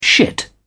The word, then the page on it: shit: British English